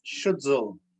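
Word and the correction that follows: Stützle: Stutzel. tout cela. Oui, ça, ça, c'est. Ça, se prononce comme Marco Sturm.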